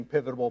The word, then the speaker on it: pivotal: I think all of them have their own moments of being pivotal points. I can't say that word very much today because I can't say it right. Pivotal. I keep saying pivotable. That's not a word.